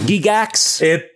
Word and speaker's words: Gygax: It's pronounced Gygax.